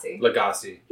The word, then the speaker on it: Lagasse: Lagasse.